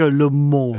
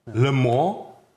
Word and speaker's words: Le Monde: Man sagt übrigens Le und nicht... Le. Le. – Und die Franzosen sagen immer, ich kann kein Ö aussprechen, wo ich immer sage, sage Le Monde. Le. Le Monde.